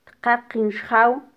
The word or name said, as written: tqaqin xjaw